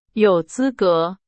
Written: 资格

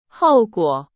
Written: 后果

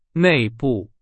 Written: 内部

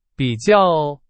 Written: 比较

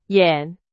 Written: 演

